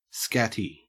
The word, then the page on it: scatty: Australian English